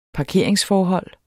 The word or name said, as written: parkeringsforhold